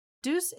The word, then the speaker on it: Deux: Deuce is pronounced like the English word deuce, D-E-U-C-E, but it's spelt like the French word for two, D-E-U-X, which made it a fitting name for the duo.